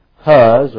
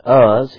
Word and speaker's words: Uz: Huz, or we pronounce it Uz, his firstborn, and Buz, his brother.